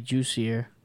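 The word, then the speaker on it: Jussier: Hussier Formiga versus, I can't say his first name. – I think they actually say Juicier. – Juicier? – I think I mostly heard people pronounce that J.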